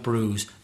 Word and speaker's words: bruise: Be careful with the spelling of bruise. B-R-U-I-S-E. Strange one. – But the vowel sound is oo, like you. So it's bruise. Bruise. Rhymes with cruise.